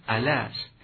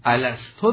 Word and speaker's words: الست: در هر صورت تلفظ درست این در واقع فعل الستو هست، یعنی باید گفت روز الستو. اما از آنجا که در زبان پارسی این حرکت به سکون تبدیل شده و بر زبانها جاری است، ما نیز همچنان میگوییم و تلفظ میکنیم.